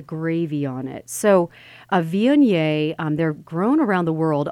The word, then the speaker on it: Viognier: So I would say my go-to wine, if it's a white wine at Thanksgiving, is called Viognier. And that, I'm going to spell that. It's V-I-O-G-N-I-E-R.